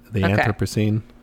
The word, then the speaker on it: Anthropocene: You know, like, there's a lot of talk right now about the Anthropocene. Is that how you say it? – Yeah. The Anthropocene.